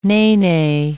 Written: nene

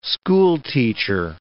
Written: schoolteacher

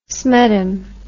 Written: smeddum

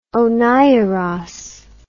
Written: oneiros

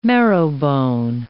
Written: marrowbone

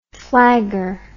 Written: flagger

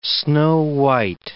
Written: snow-white

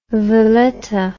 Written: Valetta

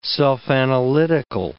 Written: self-analytical

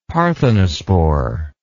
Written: parthenospore

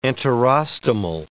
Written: enterostomal

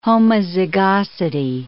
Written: homozygosity

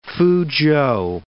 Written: Fuzhou

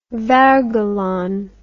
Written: wergeland

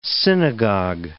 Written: synagogue

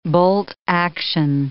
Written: bolt-action

